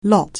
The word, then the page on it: lot: anglais